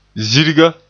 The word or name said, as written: zirga